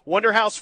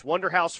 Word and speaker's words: Wunderhaus: Wonderhouse Furniture Consignment. That's spelled WUNDERHAUS. Wonderhouse Furniture Consignment.